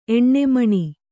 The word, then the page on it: ಎಣ್ಣೆಮಣಿ: Kannada